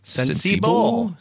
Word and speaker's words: Sensibo: So I bought this thing called Sensibo. I don't know how you pronounce it. – Sensibo. – Yes. S-E-N-S-I-B-O.